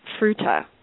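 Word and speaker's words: Fruita: So I'm curious what it was like growing up in a town like Fruta, pronounced correctly.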